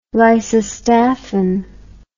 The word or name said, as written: lysostaphin